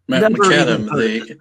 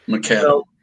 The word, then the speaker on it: McAdam: McAdam, like the inventor of the asphalt road. McAdam.